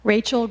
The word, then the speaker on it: Rachel: Rachel Gould, R-A-C-H-E-L-G-O-U-L-D.